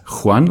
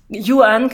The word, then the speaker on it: Yuan: Wir haben vorhin überlegt, heißt das Juan oder Yuan eigentlich? – Yuan, kann ich gleich gerne was dazu sagen.